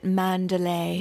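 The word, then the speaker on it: Manderley: It's funny because it's spelled Manderly. – Oh, right. – But I think they say it, like in the movie, they said it Mandalay.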